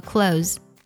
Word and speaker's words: clothes: 如果呢按照音标读应该是 clothes，这样读呢是正确的，但是在口语当中呢这样读是比较费力的，所以呢老外也经常呢会把 th 音省掉，直接读作了 close。对了，就是那个 close the door，关闭的那个 close，读作 close。